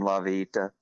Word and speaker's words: La Vita: There's also a street named La Vita. So anyway, that's how you say it, La Vita. It's a Spanish word.